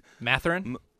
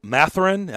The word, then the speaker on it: Mathurin: I didn't know much about him, but Benedict Mathurin. – Mathurin. I was gonna say, how do you say his last name? Mathurin.